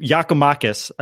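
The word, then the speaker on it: Giakoumakis: Whatever the Greek, Yakamakis, I believe, is how I learned how to say it.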